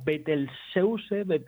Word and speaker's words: Betelgeuse: Bueno, parece ser que la pronunciación está más parecida al español que al inglés. Entonces sería algo así como Betelgeuse, estaría más parecida a como lo pronunciamos nosotros.